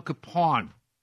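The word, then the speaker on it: Kapaun: His name is pronounced differently depending on who you talk to, but I guess the family says that the proper way to pronounce it is the Bohemian way, which is what he was, Father Emil Capon.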